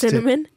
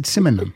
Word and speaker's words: Simonon: I can never say his name. Simonon, I think. – Just cinnamon? – No, not cinnamon. I said Simonon.